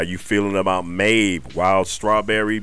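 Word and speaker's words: Maebh: Maeve is spelled M-A-E-B-H. I don't know how the hell that's Maeve, but I did look it up. That is how it's said, so I'm gonna leave it right there.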